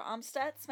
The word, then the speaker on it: Amstutz: Yes, you can find me on all social platforms at Sandra Amstutz. My last name is spelled A-M-S-T-U-T-Z.